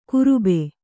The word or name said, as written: ಕುರುಬೆ